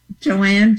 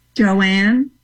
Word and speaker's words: Joanne: Joanne Jacquart. – Spell that. – J-A-Q-U-A-R-T. – So hold on, say the whole thing again slowly. – Joanne Jacquart. Yeah, as an author. – Spell both words. – J-O-A-N-N-E J-A-C Q-U-A-R-T, Joanne Jacquart.